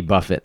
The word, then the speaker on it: Buffett: Jimmy buffets. – Well, you may have some assault charges if you throw that. – You don't want to throw Jimmy Buffett is actually how you pronounce it, and I apologize for that.